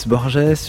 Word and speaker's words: Borges: Merci de nous retrouver pour une nouvelle semaine de la compagnie des auteurs, en compagnie cette fois de José Luis Borges, une émission de Didier Pinault, Anne-Vanessa Prévost, Chloé Caxmarek, Laurence Millet, Laurence Yenpin et Pierrick Monsigny. Borges, pour le prononcer à la française, est une référence littéraire absolue pour de nombreux écrivains et nous allons tenter de comprendre cette semaine pourquoi.